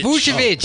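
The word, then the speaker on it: Vučević: I'm going to butcher his last name. I never know how to say it. Nikola Vucevic. – Vucevic. Oh, perfect, yeah.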